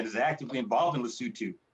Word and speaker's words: Lesotho: it's Lesotho, and I said it wrong for years, and I'm sorry about that to the country of Lesotho.